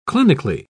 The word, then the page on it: clinically: British English